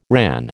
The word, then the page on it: RAN: American English